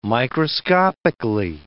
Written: microscopically

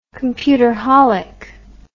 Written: computerholic

